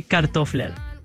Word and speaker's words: kartofler: En danés, papas se dice kartofler.